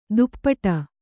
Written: ದುಪ್ಪಟ

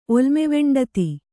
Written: ಒಲ್ಮೆವೆಂಡತಿ

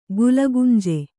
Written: ಗುಲಗಂಜಿ

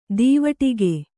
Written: ದೀವಟ್ಟಿಗ

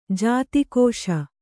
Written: ಜಾತಿಕೋಶ

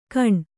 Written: ಕಣ್

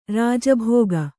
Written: ರಾಜಭೋಗ

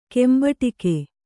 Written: ಕೆಂಬಟಿಕೆ

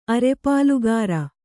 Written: ಅರೆಪಾಲುಗಾರ

